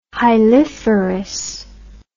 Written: hiliferous

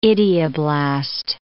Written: idioblast

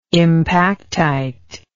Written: impactite

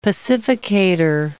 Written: pacificator

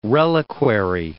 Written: reliquary

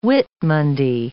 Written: Whitmonday

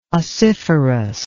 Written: ossiferous

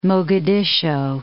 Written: Mogadiscio

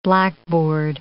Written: blackboard

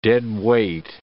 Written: deadweight

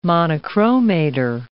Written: monochromator

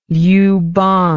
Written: Liupang

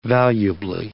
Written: valuably

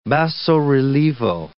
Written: basso-relievo

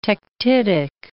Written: tektitic

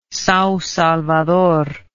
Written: Sao Salvador

